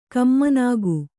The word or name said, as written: ಕಮ್ಮನಾಗು